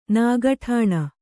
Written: ನಿಗಠಣೆ

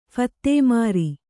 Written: ಫತ್ತೇಮಾರಿ

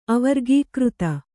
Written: ಅವರ್ಗೀಕೃತ

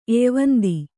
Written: ಎಯ್ವಂದಿ

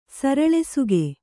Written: ಸರಳೆಸುಗೆ

